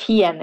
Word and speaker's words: Hien: t and my second first name is Hien. It's spelled T H I. The second uh, first name is H I E N.